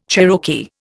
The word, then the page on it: Cherokee: Indian